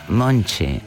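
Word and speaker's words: Monte: mentre la cantante si chiama sì Marisa, ma si pronuncia Monchi, va bene. Mo impariamo anche la pronuncia brasiliana, itch e altro.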